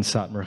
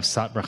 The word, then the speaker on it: Satmar: It's from the way the Jews pronounced the name of Romania, where they were from, which was Satu Mare, which was named for Saint Mary.